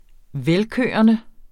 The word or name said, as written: velkørende